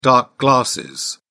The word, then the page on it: dark glasses: British English